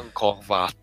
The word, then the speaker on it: Angkor Wat: É, a gente tem, por exemplo, bastante citado como exemplo, Belfor Roxo, Meca, Puma Punku, Teonakum, Coral Castle, Angkor Wat, que provavelmente é algo que eu errei a pronúncia, mas que tem no Civilization. – É o Angkor Wat.